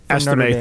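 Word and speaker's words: Estime: Estimate, thank you, from Notre Dame.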